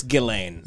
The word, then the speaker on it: Ghislaine: Ghislaine? – I think it's Ghislaine. – If you respect her, it's Ghislaine.